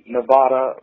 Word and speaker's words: Nevada: Those in Nevada like to call it Nevada, but I don't call it that because they don't call Texas with a long A, and that would be that.